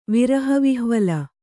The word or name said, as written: ವಿರಹವಿಹ್ವಲೆ